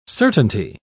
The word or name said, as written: certainty